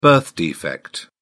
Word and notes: birth defect: British English

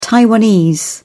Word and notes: taiwanese: British English

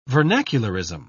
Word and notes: vernacularism: British English

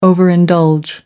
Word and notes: overindulge: American English

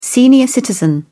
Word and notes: senior citizen: British English

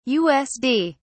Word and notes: usd: British English

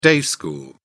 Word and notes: day school: British English

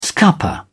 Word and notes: scupper: British English

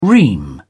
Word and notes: ream: British English

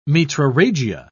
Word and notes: metrorrhagia: British English

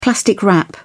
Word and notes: plastic wrap: British English

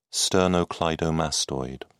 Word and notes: sternocleidomastoid: British English

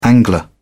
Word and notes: angler: British English

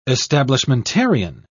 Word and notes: establishmentarian: British English

